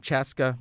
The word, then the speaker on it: Chaska: And now, MacGrad99, also known as Celi Haga, also known as Cece, is on the phone with me from Chaska, Minnesota. Is that how you pronounce that, Celi? – That is correct.